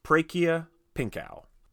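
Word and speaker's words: Prachya Pinkaew: I did get the director, whose name is pronounced Prakia Pinkow. Again, I can't verify this.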